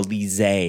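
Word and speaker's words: Lysée: is Lisée. Or Lise, I guess it, phonetically on the website, it says Lise, which I think is the more French way of pronouncing it.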